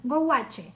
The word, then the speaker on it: gouache: Aquí está es el go, gouche, gouache. Lo voy a pronunciar así como bien latina, gouache.